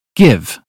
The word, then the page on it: give: British English